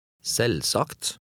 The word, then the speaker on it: Selvsagt: In number 12, the V is silent. – Les nummer 12. – Selvsagt.